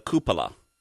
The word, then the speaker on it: cupola: Yeah, and on the end of the node is the cupola.